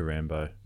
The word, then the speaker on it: Rambeau: Monica Rambeau. Not Geraldine. – Every time I hear it last one, I just think of Rambo. – Rambo. – Even though it's spelled differently, it's like, damn it.